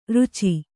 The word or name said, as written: ರಚ್ಚೆ